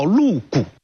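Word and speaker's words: 陆贾: 当时有一个儒生名叫陆谷，这个陆谷的谷啊就是贾宝玉的贾，读作谷。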